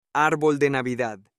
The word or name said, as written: arbol de Navidad